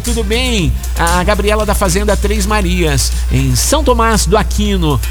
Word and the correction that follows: São Tomás de Aquino: É, São Tomás de Aquino, né? Aí, sim. Agora falei certo.